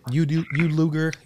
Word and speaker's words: Yudlugar: Is that how you pronounce it? Yudlugar? – Yeah, I say Yudlugar, but you can say it how you want.